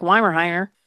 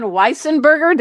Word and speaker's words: Weissenberger: It almost sounds like Weimerheimer. – Yeah, it's hard to pronounce. – It's Arthur von Weissenberger. Did I get it right? – You certainly did.